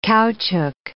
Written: caoutchouc

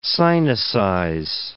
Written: sinicize

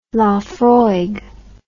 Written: Laphroaig

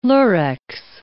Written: Lurex

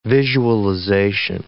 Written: visualization

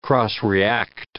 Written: cross-react

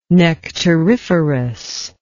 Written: nectariferous

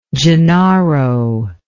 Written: gennaro

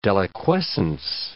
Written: deliquescence

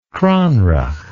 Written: cranreuch

